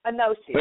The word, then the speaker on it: Anoshi: Anoshi.